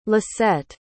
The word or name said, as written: lissette